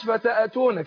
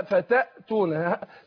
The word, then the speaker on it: فتأتون: ما قلش فتأتون، كتير من الناس بينطقوها كده فتأتون، يحرك الايه الهمز، لا فتأتون سكون، فتأتون فتأتون أفواجا.